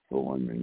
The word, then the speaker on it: Philemon: Titus is right before Philemon. Let me find that here. – Yeah, boy, you pronounced that pretty good, Philemon, yeah.